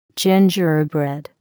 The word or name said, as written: gingerbread